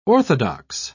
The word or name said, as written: orthodox